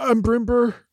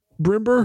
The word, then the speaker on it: Brombur: That would be B-R-O-M-B-U-R, Brimber. – Hmm. Oh, interesting. – It might be Brimbeer. I don't know how to pronounce my name.